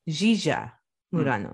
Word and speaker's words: Yiya Murano: Oh, I'm sorry, I'm saying that wrong already. I'm saying it how I spell it, but it's actually Yiya Murano. – Mm. Oh. – Yeah. – So the Y is a J? – It's kind of like a Z.